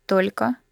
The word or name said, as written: only